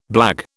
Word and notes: blague: British English

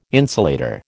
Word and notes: insulator: American English

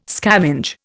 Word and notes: scavenge: Indian

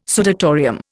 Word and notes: sudatorium: Indian

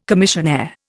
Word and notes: commissionaire: Indian

